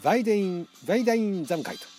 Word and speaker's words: Waldeinsamkeit: これねバルデインザムカイトみたいな、ね、そんな発音でしたけど、 WALDEINSAMKEIT とバルデインザムカイトっていうね、あのドイツ語では W がブって言いますからね、あのベームベーみたいなね、バルデインザムカイトっていうのが、